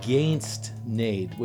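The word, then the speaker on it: Geins't Nait: It is not Geins’t, Nait, which is what I was saying. It is Geins’t, Nait.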